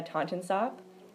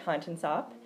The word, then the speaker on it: Tontensop: The YA is silent at the end. Great, and you have it now. – Yes. – So, as I said, I'm your host, Susanna Tontensop, and I'm here with my guest, Pete Weiss.